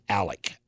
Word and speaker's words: Alex: It's spelled Alex, but he pronounces it Alec.